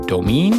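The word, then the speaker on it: Dominé: He likes The World of Spirits and Cocktails, the Ar- Ultimate Bar Book by Andre Domain, I think is how you pronounce it. D O M I N E with a uh, asterisk, a uh, accent on the on the e at the end there.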